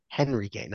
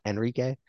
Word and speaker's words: Henrique: Henrique, not Enrique. They Yeah. – pronounce it Enrique in Spain because the H is silent, but he's Brazilian right winger.